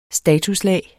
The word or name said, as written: statuslag